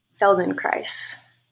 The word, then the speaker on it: Feldenkrais: How do you say his name again? Felden what? – Feldenkrais. – Oh, whoa. Is it all one word? – Yeah. FeldenKrais.